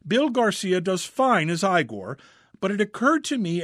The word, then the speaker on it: Igor: How long before Frederick and Igor, pronounced Igor, played by Bill Garcia, get back in the family business?